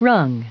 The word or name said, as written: wrung